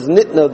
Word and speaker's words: nitna: However, we have a Kabbalah, the Ran points out, we have a Kabbalah that the way to pronounce the word is nitna, that it was given.